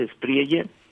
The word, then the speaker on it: Espriella: se pronuncia así, ¿verdad? Espriella. – Espriella. Quizás si es de origen italiano, en italiano sería Espriella. Pero pues aquí se lee como en castellano, así lo dicen.